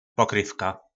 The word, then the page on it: Pokryvka: po nasimu